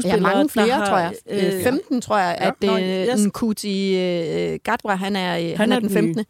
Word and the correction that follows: Nshuti: Og jeg har siden sidst lært at udtale hans navn. Han hed ikke Nkuti, han hed Nshuti.